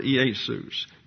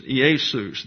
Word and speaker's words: Iesus: There are three syllables here in the word Iesus. The iota, the eta, the sigma, and then O-U-S. Iesus.